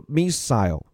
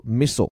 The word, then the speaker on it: missile: 这里 Ukrainian 形容词乌克兰的后边这个 missile 英国人是这么念的，美国人呢叫念作 missile，但都是一个意思啊，就是导弹。